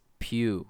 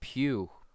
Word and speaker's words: Pugh: I think it's pronounced Pugh or not Pugh. Pugh. Yeah, like yeah it's P U G H.